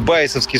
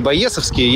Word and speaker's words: байесовский: Артем меня сразу же поправил, сказал, что надо говорить байесовский с ударением на «а», не байесовский. Я всегда говорил с ударением на «е».